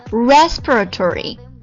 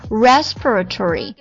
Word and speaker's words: respiratory: 要重点看一下这个形容词呼吸的怎么读，跟我来一起念一下 respiratory。通常来这个 per 它会有点滑，很快啊。 respiratory，大家就记住它重音呢在第一个 respiratory， respiratory。